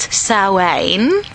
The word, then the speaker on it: Samhain: In witchcraft, we actually pronounce it Samhain.